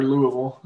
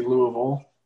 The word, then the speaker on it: Louisville: You know, I was going to talk about Syracuse Louisville, but it, or Louisville. I'm sorry. I'm not sure who pronounces it the way, you know, I mean, you're going to offend someone either way you pronounce Louisville. So I try to be more traditional and say Louisville, but I slipped up that time.